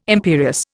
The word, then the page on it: imperious: Indian